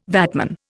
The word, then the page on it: VATman: Indian